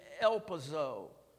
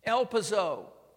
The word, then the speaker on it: elpizo: And the Greek word there is elpizo. E-L-P-I-Z-O. The accent is on that O.